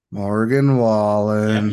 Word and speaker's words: Morgan Wallen: And then for the first time headlining Stagecoach in 2024 is Morgan Wallen. Yeah, you gotta say his name like that.